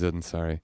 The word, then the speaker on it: Ansari: Ansari.